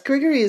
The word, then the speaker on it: Grigory: The ser- servant Grigory, is that how we pronounce his? Yes, Grigory is like very, very steady as a character.